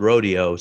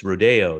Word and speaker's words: Rodeo: There's Rodeo Drive, but it's spelled Rodeo. So that's when you know someone's from out of town. – Rodeo Drive. – But it's Rodeo. It's much, much, much fancier if you say Rodeo.